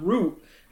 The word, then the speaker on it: route: I hear you going Hot Root, and I'm like, I say route.